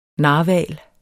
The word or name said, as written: NARHVALEN